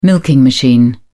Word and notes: milking machine: British English

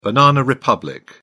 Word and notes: banana republic: British English

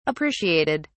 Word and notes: appreciated: British English

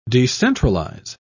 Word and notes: decentralise: British English

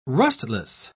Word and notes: rustless: British English

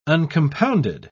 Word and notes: uncompounded: British English

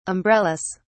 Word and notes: umbrellas: British English

